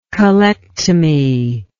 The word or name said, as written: colectomy